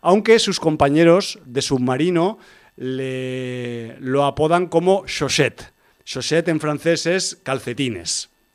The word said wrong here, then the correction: chaussette: Tal como lo has pronunciado, parece otra cosa. – Bueno, pues chaussette, que sepáis que no es otra cosa, sino calcetines en francés, ¿vale?